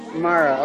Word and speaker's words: Mara: It is Mara. – Mara. Okay, good. We said the name correctly.